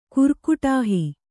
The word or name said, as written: ಕುರ್ಕುಟಾಹಿ